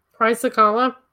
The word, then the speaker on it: Chrysocolla: Chrysocolla? – It is pronounced Chrysocolla.